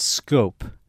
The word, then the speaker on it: Schoop: Yeah, that's a difficult name to pronounce. It's Scope. Ah, Jonathan Scope. – That's right. – But that's a very tough one. – I forgot, but when you look at it, because it's spelled S-C-H-O-O-P.